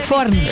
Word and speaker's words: fornos: É fornos. – É fornos que diz. – O plural pronuncia-se com vogal aberta.